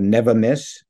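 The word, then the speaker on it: Neva Myss: It's called Never Miss, because, you know, peaches often get frosted by late spring frost. So I said Never Miss peach would miss those frosts. But it's spelled N-E-V-A, new word, M-Y-S-S.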